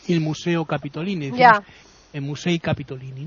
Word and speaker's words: Musei Capitolini: Bueno, los museos capitolinos, que sabéis que en italiano se dice Musei Capitolini.